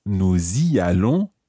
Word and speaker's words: nous y allons: And notice that we pronounce the s of nous y allons. Okay, the nous has the s pronounced here because it's followed by a vowel sound.